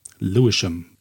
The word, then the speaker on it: Lewisham: Geschrieben Lewisham, aber gesprochen Lewisham-Straße nach dem, einem Stadtbezirk in der britischen Hauptstadt London. Lewisham. Lewisham. Lewisham.